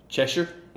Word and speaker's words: Cheshire: How do you say the cat with the Cheshire? – Okay.